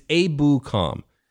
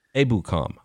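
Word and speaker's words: Ebukam: Be back tomorrow talking Samson Ibukam and the latest. Sorry, it's Abukam. Abukam. – We'll get it. We got time. – Yeah. Got to get all the syllables correct. I know the middle syllable's boo for sure.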